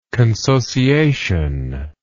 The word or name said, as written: consociation